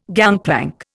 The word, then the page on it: gangplank: Indian